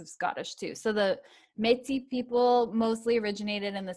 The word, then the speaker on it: Métis: Anyway, so the Inuit are the Arctic, and then the Métis. I'm only saying it like that because they say it's a French word, so I'm just going to pronounce it the French way.